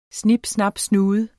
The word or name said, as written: snip